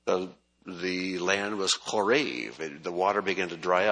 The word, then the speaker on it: Horeb: Now, in Hebrew, this name, Horeb, is pronounced Korev. Korev. And the word Korev means basically to dry up.